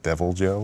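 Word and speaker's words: Deviljho: They're releasing a new update next week or on the 22nd, they're releasing a monster called, I think it's pronounced Devil Joe or something like that. It's spelled Devil with J-H-O at the end.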